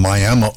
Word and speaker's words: Miami: Before Shania, we had Keith Anderson on and Picking Wildflowers, a young man from Miami, Oklahoma. And yes, that's how you say it. There's Miami is in Florida. Miami is in Oklahoma, spelled the same way.